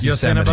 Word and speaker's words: Yosemite: You didn't say it right, but we know what you meant. Yes, Yosemite.